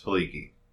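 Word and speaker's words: Helike: Well, it's spelled he like, but it's pronounced Haliki.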